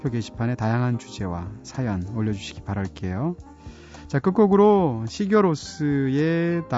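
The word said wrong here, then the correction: Sigur Rós: 다우달론이라는 노래 준비했습니다. 정확한 발음은 시우르로스가 맞다고 그러죠.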